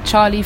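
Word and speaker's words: Charli: You can find me on YouTube, Charlie Fletcher. That's C-H-A-R-L-I and then Fletcher, you know.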